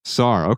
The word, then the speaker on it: Sartre: She had the expression I imagine Jean-Paul Sartre, how do I pronounce it? – Jean-Paul Sartre. – Sartre, okay, thank you.